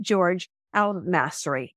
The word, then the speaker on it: Georges El Masri: It's Georges L. He says it spells Georges, but it's not. He pronounces it George L. Mastery.